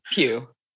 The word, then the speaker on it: Pugh: Pugh.